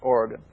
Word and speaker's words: Origen: O-R-I-G-E-N, some pronounce it Oregon, I pronounce it Oregon,